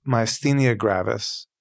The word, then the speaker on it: myasthenia gravis: Eventually, we found out that I have myasthenia gravis.